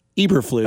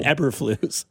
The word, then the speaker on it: Eberflus: And then like Ross Tucker was like, actually, it's Eberflus, not Eberflus.